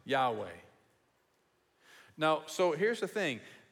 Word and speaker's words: Yahweh: Up here, we couldn't do small caps, so it's just capital L-O-R-D. And that's translating something altogether different. That's translating these four Hebrew letters, Y-H-W-H, which most biblical scholars say should be pronounced Yahweh.